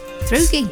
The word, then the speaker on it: througie: But it is spelt T-H-R-O-U-G-I-E. – Thrugi. – Thrugi. Thank you.